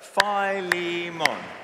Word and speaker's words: Philemon: So Philemon. Philemon. And there are different ways that you can pronounce it, but just for today, that's how we're going to pronounce our names.